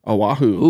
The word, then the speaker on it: Oahu: We had Windfish review a long, long time ago, and Windfish Plus One says, "Hollering at you from the great island of Oahu."